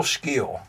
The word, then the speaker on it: Scheele: So if I told you, his name is spelled S-C-H-E-E-L-E. what would you think without like, me saying that he's Swedish and his name is Scheele? – Maybe Scheele?